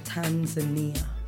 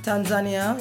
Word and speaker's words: Tanzania: Many people have two pronunciations for that country. Some people say Tanzania, and some people say Tanzania. So, anyway you want to pronounce it, whichever way floats. Tanzania, yeah, Tanzania, I don't, hey.